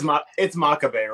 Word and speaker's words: Makabe: Or how do you pronounce the last name? – You're the one who lived in Japan for years, so I don't know. Like it's Makabe, right? – But it's like, Makabe, right?